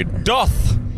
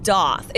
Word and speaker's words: doth: People say doth. – Oh, I've never heard it said that way. – It's not doth. It's like the word does. It's doth. It's a short U vowel sound.